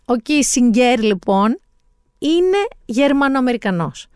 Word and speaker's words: Kissinger: Το όνομά του γερμανικά προφέρεται Κίσιγκερ. Οι Αμερικανοί όμως που στην Αμερική ήταν υπουργό εξωτερικών και έζησε όλη του τη ζωή, όπως και οι Βρετανοί τον λένε Kissinger.